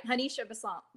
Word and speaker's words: Hanisha Besant: Hanisha Besant.